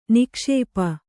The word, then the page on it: ನಿಕ್ಷೇಪ: nikṣēpa